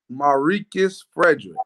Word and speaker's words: Marques Fredric: Marques Fredric.